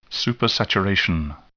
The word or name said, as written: supersaturation